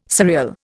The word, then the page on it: surreal: Indian